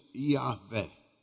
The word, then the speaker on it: Yahvé: A gente pronuncia Yahvé.